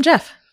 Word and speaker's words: Gef: It's spelled G-E-F, but then in parentheses it says J-E-F, like pronunciate. – Like not like Hefe. – Or Hef. I was going to say Gef. – I thought maybe Gef, but it says J, like it's pronounced like J-E-F. So I'm going to call him Jeff.